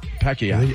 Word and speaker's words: Pacquiao: Pacquiao. – That's how they say it. Pacquiao.